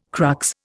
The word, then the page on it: crux: Indian